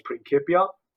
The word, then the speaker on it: Principia: I insist that it's Principia because